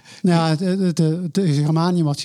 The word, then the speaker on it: germanium: U, bent ook bezig met geranium, zeg ik het goed? Germanium. Germanium, zo, ja, gallium en germanium.